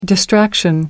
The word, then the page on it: distraction: American English